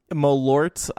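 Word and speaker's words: Malört: Malort, I believe it's pronounced. – Oh, it's Malort with the T.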